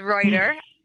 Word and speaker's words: Reutter: It's very simple, Gabriella with one L dot my last name, which is very hard to say, but it's Reuter, but it's spelled R-E-U-T-T-E-R. And it's the same on Facebook, Instagram, and TikTok, and YouTube. So very simple, Gabriella dot Reuter.